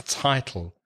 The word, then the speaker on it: title: The vowel sound is I, like my or by or try. So it's title.